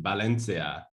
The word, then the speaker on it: Valencia: Well, V's in Spanish are pronounced like B, so it's actually Valencia.